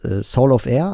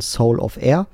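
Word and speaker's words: Solovair: Genau, dann gibt es noch einen Laden, beziehungsweise gibt es noch einen Hersteller, der heißt Soul of Air, also Solovier. Solovier wird er geschrieben, aber ausgesprochen Soul of Air.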